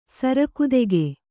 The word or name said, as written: ಸರಕುದೆಗೆ